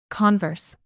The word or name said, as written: converse